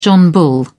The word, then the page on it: john bull: British English